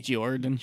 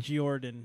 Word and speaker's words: Giorand: Like, there's a dude, Jordan Chase, but his name isn't spelt Jordan. It's spelt like... G-I-O-R-A-N-D. – Jordan. – Jordan. Yeah. Yeah. – So it makes you say Jordan? – Yeah.